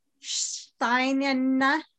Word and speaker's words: Sheinina: Sheinina.